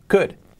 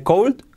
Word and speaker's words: could: Could. Nada de cold, could.